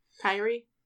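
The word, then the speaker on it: Kairi: Uh, obviously, picked up the win against uh, Kerry Sane, kind of. Kyrie. Is that, what, is that how it's pronounced? Mm-hmm.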